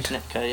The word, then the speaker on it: Schnittke: And the next song you gave us is by Alfred Schnittke. Hope I said that right. – Schnittke, yeah.